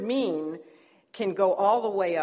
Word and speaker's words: min: Now, the word for kind in the Hebrew is mean. Like, wow, you're really mean, except it's spelled M-I-N.